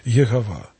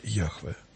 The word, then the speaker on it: Яхве: И в XIX веке почти все ученые считали, что надо произносить это имя как Йегова. Мы знаем, что некоторые святые отцы произносили так это имя, Феофан Затворник. В толковой Библии Лопухина сплошь и рядом мы видим Иегова, Иегова, Иегова. В комментариях, которые были сделаны в конце 19-го, начало 20 века на весь текст книг Библии. Но уже сейчас в библеистике общепринятой транслитерации этого имени считается имя Яхве.